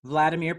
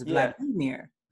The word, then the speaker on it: Vladimir: Vladimir Putin. – Vladimir Putin. – That's what most people would say, but it's Vladimir. – Vladimir. Vladimir Putin. – Vladimir Putin.